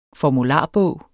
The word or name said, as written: formularbog